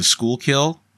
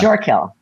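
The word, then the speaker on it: Schuylkill: The shore kill. – Yeah. It's spelled shool kill, but it's, yeah, you know, talk about growing up in where you can't pronounce the name, you know.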